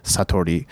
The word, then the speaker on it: satori: In Japan, they have a word called satori. I think marketing expert Eben Pagan actually said satori, but in Japanese it would be pronounced satori.